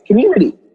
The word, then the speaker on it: Qmooniti: And a lot of people don't know how to pronounce it because it's spelled Q-M-O-O-N-I-T-I, but it's just the same way you pronounce C-O-M-M-U-N-I-T-I, right? It's Community.